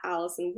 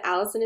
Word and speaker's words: Alisson: And on Instagram, I'm at Alisson Wood. Alisson is spelled kind of funny, Alisson.